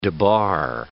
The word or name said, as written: debar